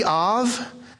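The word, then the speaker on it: Av (אב): The A-B, Av, in Hebrew, pronounced with a V instead of a B.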